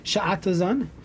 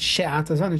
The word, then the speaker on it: sha'atazan: Sha'atazan, it's kamatz tachas Hashem, not she'atazan, it's sha'atazan.